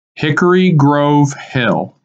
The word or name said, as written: Hickory Grove Hill